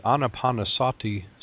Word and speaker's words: Anapanasati: It's the Anapanasati Sutta, and it's spelled like it sounds, A-N-A-P-A-N-A-S-A-T-I, Anapanasati Sutta.